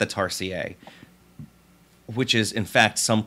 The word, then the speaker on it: tarsier: You're saying a tarsier is actually how it's pronounced. – Tarsier? – But like a dossier? – Yes, tarsier.